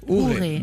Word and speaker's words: Hure: Autre titre de Commanda Hobbs, un extrait de cet album Youré, je ne sais plus si je prononce bien. – Je pense qu'on dit Ouré, d'accord. – H-U-R-E.